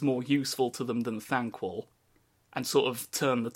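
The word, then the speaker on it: Thanquol: Is that Thankwell? – Yeah, am I saying that right? Yeah, and Ikkit Claw are basically conspiring to get rid of him. And Thankwell has the ear of the council.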